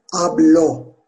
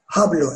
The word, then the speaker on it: hablo: Ablar H harfiyle yazıyor diyor ama ablar okunuyor. Ve birinci tekilde karşılığı ablo. Ablo. Yani İspanyolca biliyorum demek. Ablo İspanyol Büyük bir ihtimal ekran okuyucusu H harfini okuyacak. Hablo İspanyol diyecek. Fakat H harfini okumuyoruz arkadaşlar. Hablo İspanyol.